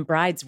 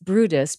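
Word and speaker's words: Bryde: Well, I always used to call them bride's whales when I first saw it, right? But it's pronounced Brutus because they're named after Johan Brutus or something like that, Johan Brud.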